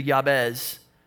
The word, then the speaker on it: Jabez: The reason that this all connects is his name Jabez, or what was probably pronounced closer to Yabez, sounded like the Hebrew word for pain.